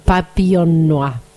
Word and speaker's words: Papillon Noir: Esa es la pronunciación, Papillon Noir.